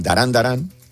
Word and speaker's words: Duran Duran: Claro, a mí me pasó, en unas pruebas para la radio que presenté el grupo Durán Durán, Darán Darán, que es como se pronuncia.